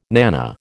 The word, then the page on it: nana: American English